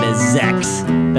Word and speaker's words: Xex: And right now we are closing out tonight's program with the aptly titled track End by Boise based thrash metal band Zex, that is spelled X-E-X.